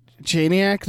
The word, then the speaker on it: Janiak: Yeah, it's somebody that I, Lee Janik. Am I saying that right? – Janiak, maybe? – Janiak.